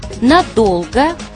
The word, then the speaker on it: надолго: Как правильно, задолго или задолго, надолго или надолго, ненадолго или ненадолго? Запомнить легко. Во всех этих словах ударение такое же, как у слова «долго». Задолго, надолго, ненадолго.